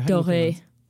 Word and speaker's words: Doerrie: D-O-E-R-R-I-E. – What's the German pronunciation of that? That's got to be like Dory or Dory. – Dory, Dory.